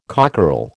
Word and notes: cockerel: American English

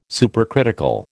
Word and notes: supercritical: American English

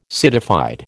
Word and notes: citified: American English

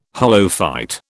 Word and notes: holophyte: British English

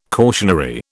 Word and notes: cautionary: British English

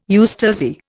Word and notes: eustasy: Indian

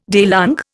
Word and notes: daylong: Indian

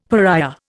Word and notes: pariah: Indian

